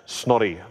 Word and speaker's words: Snorri: S-N-O-R-R-I, but you pronounce it Snotty, like that, Snorri Sturluson.